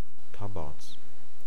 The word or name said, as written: Tabarz